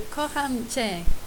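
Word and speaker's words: Kocham Cię: Chakam Si Gi. – So, Kocham Che.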